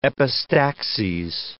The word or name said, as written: epistaxes